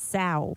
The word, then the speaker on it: Sao: If you're going to be finicky about it, it's got to be pronounced the Sao Biscuit. – As in cow with an S, Sao.